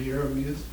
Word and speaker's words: Jeremias: Jeremias.